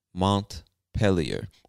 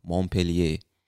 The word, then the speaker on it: Montpelier: And the capital of this state is Montpelier. Now, I believe that's the English-speaking pronunciation of the word, but this word looks French to me, so some people from Vermont might say Montpellier.